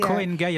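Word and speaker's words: Koh Ngai: Vous avez l'île de Koh Nangai également qui est Koh Nangai, attention parce qu'on prend des notes.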